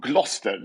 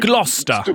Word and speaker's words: Gloucester: Så det uttalas double Gloucester. Det är ju Gloucester. – Brittisk stavning. Gloucester. Så brittisk stavning är spännande alltid.